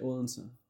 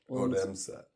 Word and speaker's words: Odense: It's like, in American, I would say Odense. – Odense. Okay. – Yeah, I'll say it like that.